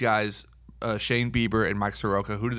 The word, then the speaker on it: Soroka: We debated about how to say his name, but what did we settle on? Soroka? – Soroka. – Soroka. Mike Soroka.